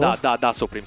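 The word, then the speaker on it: Tha Supreme: Ma non siamo qui per parlare di quanto è bravo Ta Supreme, che sì, è bravo. – Da Supreme con la D. Ah. Cioè, si scrive con la T, ma si legge con la T.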